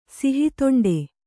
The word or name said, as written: ಸಿಹಿತೊಂಡೆ